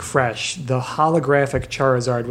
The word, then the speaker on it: Charizard: Yep, Charizard.